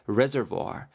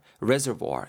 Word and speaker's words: reservoir: R E S E R V O I R인데요. reservoir, reservoir 이렇게 발음이 되고요.